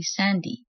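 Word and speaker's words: Sandie: My parents named me Sandra, but most people call me Sandy, spelled with an IE instead of the usual Y.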